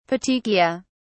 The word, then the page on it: petechiae: British English